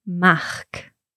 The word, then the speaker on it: Mac: So in English we say Mac, M-A-C. It's spelled the same way in Gaelic, but it's pronounced Mach. – Mach, but it's spelled M-A-C. – It is spelled M-A-C, yeah.